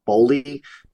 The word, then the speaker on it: Boehly: Todd, I think you pronounce it Boley, B O E H L Y.